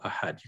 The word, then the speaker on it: ahad: You can hear the D sound at the end. Allahu samad, lam yalid, wa lam yulad, wa lam yakul lahu kufuwan ahad. So the word for one is ahad, and it ends with the D.